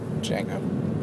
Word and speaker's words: Django: Django. Unchained. – The Django. – It's the D is silent.